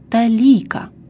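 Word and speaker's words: толика: В современном русском языке это выражение употребляется прежде всего в значении «очень немного», и произносится «малая талика». Ударение во втором слове падает на второй слог.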